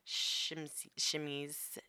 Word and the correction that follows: chemises: How do you say that? – Chemises.